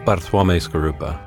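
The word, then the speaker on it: Bartłomiej Skorupa: Bartlomiej Skorupa.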